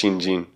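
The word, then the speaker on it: Shinjin (身心): Shin Shin, but it's pronounced Shinjin, is mind, and Datsuraku is falling away.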